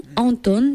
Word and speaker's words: Anton: Anton, si vous cherchez, ça s'écrit A-N-T-O-N, tout simplement.